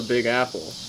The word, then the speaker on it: Big Apple: I think, so I found out it's called, the reason why it's called the Big Apple, the emphasis is on the big, not the apple.